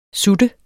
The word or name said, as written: sut